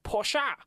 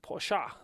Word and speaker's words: Porsche: It was a Porsche in front of me. Porsche! As the Americans call it, Porsche. They pronounce the E as a sound.